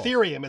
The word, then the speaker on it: Ethereum: I'll pronounce it the way it looks. But I'm not sure what it is. Etherium. And it looks like Ethereum, but I don't know what this is. – Yes. – Okay, cool. – Ethereum. It's another cryptocurrency. – Ah, very good. – And you pronounced it correctly.